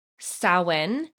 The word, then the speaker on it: Samhain: And I think it's a Celtic, it's called Samhain is how it's pronounced, but it was a festival of the dead.